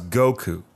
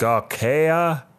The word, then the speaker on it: Goku: where he couldn't pronounce Goku. Because he's like, Gaokea.